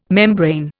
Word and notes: membrane: Indian

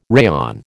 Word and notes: rayon: American English

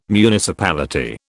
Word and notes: municipality: British English

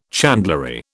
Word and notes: chandlery: British English

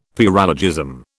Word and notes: paralogism: British English

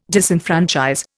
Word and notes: disenfranchise: Indian

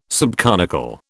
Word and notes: subconical: British English

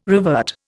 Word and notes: revert: Indian

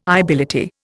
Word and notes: ibility: Indian

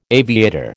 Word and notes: aviator: American English

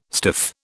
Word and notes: stiff: British English

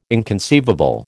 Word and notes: inconceivable: American English

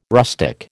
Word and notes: rustic: American English

